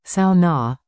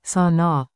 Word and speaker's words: sauna: Remember, it is the Finnish pronunciation, sauna, not sauna, on the back roads.